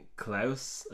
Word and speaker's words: Klaus: Yeah, so I mean, Klaus, I guess, would be the most the most accurate way to pronounce it.